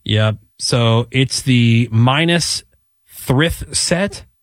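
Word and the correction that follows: Minas Tirith: Minastrith, which is the capital of Gondor.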